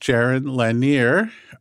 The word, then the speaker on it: Jaron Lanier: Well, in the U.S., it's usually pronounced Jaron Lanier.